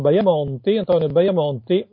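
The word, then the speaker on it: Bajamonti: Dall'altra parte avete Antonio Baiamonti, notate la I lunga intervocalica. Nella scrittura italiana fino all'inizio del Novecento, quando una I stava per fare due vocali, si scriveva sempre I lunga. Adesso questa usanza si è persa, ma si pronuncia sempre I, semplicemente intervocalica.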